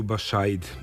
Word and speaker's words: Bašajd: Kao što je slovo Č bitno kada Bačvani izgovaraju Čurug, tako je slovo Š bitno kada Banaćani izgovaraju Bašajd, ime sela kraj Kikinde.